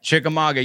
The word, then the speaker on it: Chickamauga: Is that how you say it? – Chickamauga, yeah. It's the oldest residential house in Chickamauga, yeah.